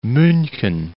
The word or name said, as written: Munchen